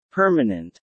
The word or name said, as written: permanent